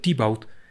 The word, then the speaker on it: Teobaldo: Para este momento, Teobaldo, quien en el lenguaje, en el dialecto local, se pronuncia Tibaut, ha comenzado a comer mucho.